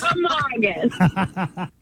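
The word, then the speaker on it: Kancamagus: Kankamagus.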